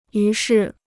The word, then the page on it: 于是: yú shì